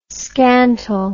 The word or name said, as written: scantle